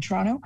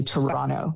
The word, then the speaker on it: Toronto: By the way, I should also say that Torontonians say Toronto, not Toronto. So if you want to assimilate and seem like a local, don't pronounce that last T.